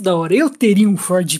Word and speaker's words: Ford: Mas é claro, é o glorioso Ford, que a gente pode até tirar. O, o, R, o R é mudo, não tem, não tem som R. Claro, o Ford Pinto.